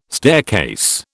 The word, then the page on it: staircase: British English